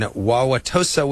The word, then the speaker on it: Wauwatosa: Aileen in Wauwatosa, Wisconsin.